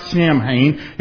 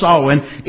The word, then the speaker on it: Samhain: It looks like Samhain. It's pronounced Samhain.